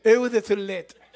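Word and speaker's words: eau de toilette: Sí, porque han mirado ustedes que dice eau de toilet. Pero no se pronuncia así, es, a ver, así. Eau de toilet.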